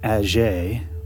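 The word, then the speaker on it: Atget: Agier, and I'll spell that for you non-Francophiles. It's A-T-G-E-T, Eugène Agier.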